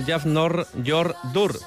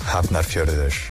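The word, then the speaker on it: Hafnarfjörður: Mañana en los cuartos en Artaleku vuelve Europa a la competición de Bidasoa con un conjunto islandés que se llama algo así como Jafnor Jordur, pero realmente se pronuncia de la siguiente manera. – Hafnar Fjörður. Hafnar Fjörður.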